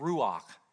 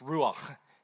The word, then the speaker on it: ruach: It's called ruach. It's ruach. If you spit at the end of it, it means you're saying it right. Ruach. Clear your throat, right?